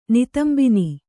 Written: ನಿತಂಬಿನಿ

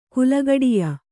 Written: ಕುಲಗಡಿಯ

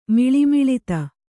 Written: ಮಿಳಿಮಿಳಿತ

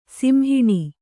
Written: ಸಿಂಹಾಣ

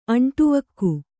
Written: ಅಂಟುವಕ್ಕು

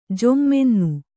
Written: ಜೊಮ್ಮೆನ್ನು

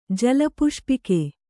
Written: ಜಲಪುಷ್ಪಿಕೆ

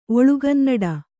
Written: ಒಳುಗನ್ನಡ

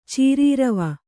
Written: ಚೀರೀರವ

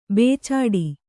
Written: ಬುಚಡೆ

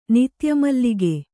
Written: ನಿತ್ಯಮಲ್ಲಿಗೆ

